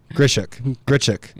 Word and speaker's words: Grichuk: It's Grichuk, right? Or Danny's laughing. – Correct. Grichuk. Yes. Grichuk.